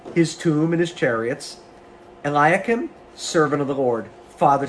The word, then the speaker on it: Eliakim: Eliakim. – Thank you. Some words I just don't click in my brain. Eliakim, I will clothe him with your robe and fasten your sash around him and hand your authority over to him.